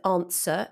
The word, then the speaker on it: answer: Don't forget with answer that it has a W, answer, but we don't pronounce that, of course. We say answer, as if there is no W there.